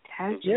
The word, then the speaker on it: Tasjan: And again, today is a special edition of the Indie Cafe with Aaron Lee Taz Jan. Didn't I say that right? – Taz Jan. Yeah.